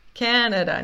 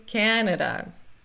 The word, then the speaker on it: Canada: Canada, não é Canadá, é Canada. Canada is the largest country in North America. E Canada, a primeira sílaba é sílaba tônica, por isso a gente fala corretamente ca, Canada e America.